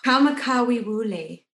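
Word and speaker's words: Kamakawi Wule (Kamakawiwoʻole): Kamakawi Wule.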